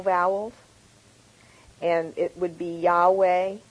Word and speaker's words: Yahweh: So there's no J in Hebrew, though. And so, you know, Yahweh is a better way to really pronounce it.